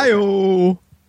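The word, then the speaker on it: I/O: That was a terrible I.O. That's how they say it, right? – Yeah.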